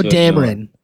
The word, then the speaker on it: Dameron: Poe Dameron. Poe Dameron.